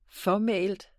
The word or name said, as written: fåmælt